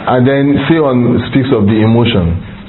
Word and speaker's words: Sihon: Sion. S-I-H-O-N. Sion. Well, so how do you pronounce that? Sion. – Zion. – Zion, okay.